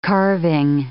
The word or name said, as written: carving